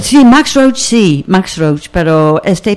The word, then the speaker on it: Max Roach: Max Roach. – Eso, sí. – Lo dije bien, lo dije Max Roach, como digo siempre.